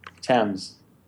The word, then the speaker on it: Thames: Is it Thames? Or is that how it's pronounced? – Thames.